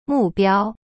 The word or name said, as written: 目标